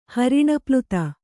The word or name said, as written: ಹರಿಣೀಪ್ಲುತ